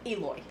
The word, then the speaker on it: Eloi: They're the Eloi. Eloi? – Eloi. – Eloi. – The Eloi and the Morlocks.